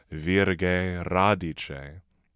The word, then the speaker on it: virga e radice: Inter rubeta lilium, columba formosissima, virga e radice, though here uh, we can see the italicized a elides into the next word, a. So we're saying virge radice.